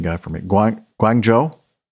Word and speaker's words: Guangzhou: Guangzhou is Guangzhou. Chengdu, Shenzhen. Beijing's Daxing, D-A-X-I-N-G airport, P-K-X, excuse me.